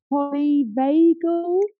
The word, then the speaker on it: polyvagal: I do have one question, and that was the polyvagal.